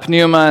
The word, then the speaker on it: pneuma: That said, this branch of theology, pneumatology, from the Greek word pneuma, and it actually, you actually pronounce the P. Okay, some people think that you never pronounce the P. Well, if you're Greek, you do. So it's pneuma.